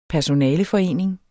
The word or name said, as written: personaleforening